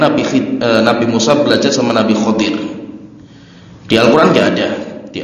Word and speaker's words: Khadir: Kalau yang masyhur itu bacanya pakainya Khadir. Jadi bisa dibaca Khadir, bisa dibaca Khidor. Tapi yang masyhur Khadir, pakainya Kho. Harokatnya difathahkan Kho-nya. Walaupun di kita seringnya dikasrokan Khidir. Tapi yang benar bukan Khidir, tapi bacanya Khadir.